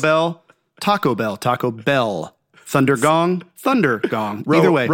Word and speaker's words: Thundergong: Thunder gong.